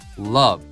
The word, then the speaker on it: love: あとはまあ行きたいんだけどっていうところをやっぱ出したいので、ね、この I'd からのこのテンションも大事だけども、まあ love の発音ね、これ O って書いてあるけど発音は口をあんまり開けない、ね、とんがりコーンのあですね。日本語のあに近いけれども口を開けないあ、あ、 love、 I'd love to but Example.